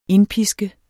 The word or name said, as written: indpiske